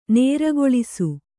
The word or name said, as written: ನೇರ್ಗೊಳಿಸು